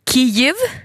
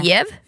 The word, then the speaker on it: Kyjiv: Yes, så svenska Yle övergår nu till Kyiv och inte Kiev. Alltså som man skriver också K-Y-J-I-V.